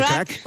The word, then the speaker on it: craic: Con lo cual ahí podremos, decirte a ti y al resto de componentes de The Corrs, what's the craic? Creo que lo he dicho bien. – What's the crack? – What's the crack? What's the crack? Es como el how are you, pero en irlandés, ¿vale?